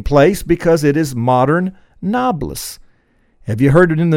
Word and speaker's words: Nablus: Napolis, because the Arabs have difficulty saying the letter B, is pronounced by the Arab-speaking peoples, Nablus.